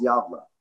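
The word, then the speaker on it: Gävle: It's like Galve or something like that, right? – Pronounced Javla.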